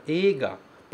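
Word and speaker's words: ega (映画): Cuidado com essa palavra ega, porque ela se escreve ei, mas ela se pronuncia e. Ok?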